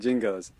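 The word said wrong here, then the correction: Jinjo: I believe it's pronounced jinjos for starters.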